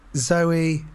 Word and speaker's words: Zowie: Now Duncan Jones, because in that case, Zowie is pronounced like the girl's name, Zoe, and not the more expressive Zowie. So it's Zoe Bowie.